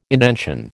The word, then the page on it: indention: American English